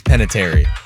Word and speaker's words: Panettiere: Did I say that right? – I think it's Panettiere.